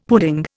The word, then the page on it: pudding: Indian